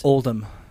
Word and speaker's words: Oldham: Oldham. – Yeah, Oldham. – I don't know. – Spelt as in old and then ham.